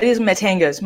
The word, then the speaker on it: Matanga's: It is Matanga's. My husband's name is Matthew. So it's Matt, and part of my name is Anga, so that's why it's called Matanga's, Matanga's Pizzeria.